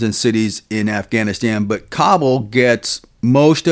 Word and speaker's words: Kabul: For the folks that are listening, some people say Kabul, others say Kabul, but the proper pronunciation is Kabul, correct? – Yeah, Kabul.